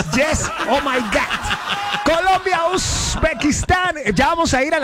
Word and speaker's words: Uzbekistán: ¿Lo dije bien? ¿Qué tal mi pronunciación? – Excelente, así se dice. – Claro que Katy, que estaba estudiando, pues, a ver, Katy, ¿cómo sería Uzbekistán? – Así es.